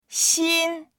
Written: xin1

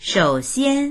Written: shou3xian1

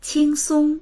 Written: qing1song1

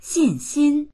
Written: xin4xin1

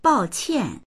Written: bao4qian4

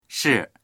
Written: shi4

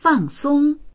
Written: fang4song1

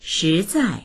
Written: shi2zai4

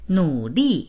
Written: nu3li4

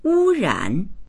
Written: wu1ran3

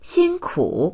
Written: xin1ku3

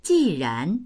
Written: ji4ran2

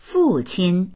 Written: fu4qin1

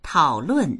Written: tao3lun4

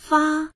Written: fa1

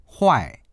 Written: huai4

